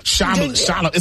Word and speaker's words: Chalamet: No, Chalamet.